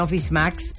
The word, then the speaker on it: Office Max: ahorita tienen una promoción en Office Max.